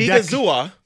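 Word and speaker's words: Odighizuwa: So the Cowboys are signing their defensive tackle, Agadazua. I always mess that name up. – Jesus, David. – Odigizua. – Odigizua. Yep.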